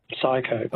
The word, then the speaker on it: psycho: Psycho. – Oh, you found it just at the last minute. I was going to say it's got a silent P, but I thought, it's too much information.